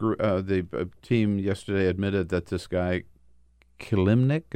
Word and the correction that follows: Kilimnik: is that how you pronounce his Klimnik. name? Yeah. Kilimnik.